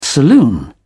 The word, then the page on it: saloon: British English